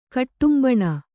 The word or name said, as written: ಕುಟುಂಬಿನಿ